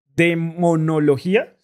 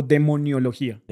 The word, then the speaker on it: demonología: Padre, em, usted dice que se, especializó en todo el tema de exorcismo, demonología, ¿se dice? ¿O demoniología? – Demonología. Ah, demonología.